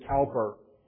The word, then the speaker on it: Cowper: William Cowper, the hymn writer, sometimes pronounced William Cowper,